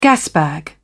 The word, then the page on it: gasbag: British English